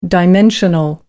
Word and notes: dimensional: American English

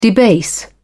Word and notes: debase: British English

